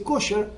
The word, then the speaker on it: kasher: Es kasher en hebreo. Lo primero que todo el mundo le dice kosher. Kosher es la pronunciación que tiene en yiddish.